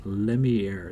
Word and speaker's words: Lemierre: Lemierre,